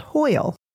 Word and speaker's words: hwyl: It's hwyl, pronounced like oil with an H, hwyl, but it's spelled H-W-Y-L.